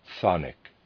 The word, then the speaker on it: chthonic: Readers aside, Thonic is spelled C-H-T-H-O-N-I-C, Thonic, end of readers aside.